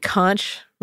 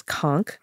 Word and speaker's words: conch: So a conch, you know, it looks like conch written out, but the pronunciation is conch.